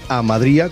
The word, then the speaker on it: Amadría: Pues hablando de cosas buenas, Amadria o Amadría, depende de cómo lo veamos eh, eh, escrito. Hay en algunos eh, sitios donde viene con acento en la I, otros sitios en los que no. En el disco, desde luego, viene con acento en la I, Amadría con H,